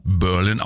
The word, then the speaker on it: Berlin: Der Titel wurde schon 1926 von Irving Berlin geschrieben, also Berlin, ich weiß nicht, er schreibt sich Berlin, aber ist natürlich als Amerikaner Berlin ausgesprochen.